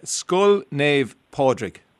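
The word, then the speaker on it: Scoil Naomh Pádraig: Skull Nave Podrick. Skull Nave Podrick. Say skull like your skull, and then nave like N-A-V-E, and then Podrick. That'll get you.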